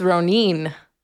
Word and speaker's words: Ronin: Well, when I was looking it up, and I'm going to do my best not to butcher absolutely everything that I say, it's Ronin. – Oh. – Yeah, but I'm... Ronin. – I've been mispronouncing that for a while.